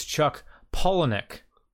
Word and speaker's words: Palahniuk: Ah, the correct pronunciation of Chuck Palahniuk is Chuck Palahniuk.